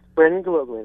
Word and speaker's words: Brandon Gilogly: Yes, Brandon Gilogly, that's me.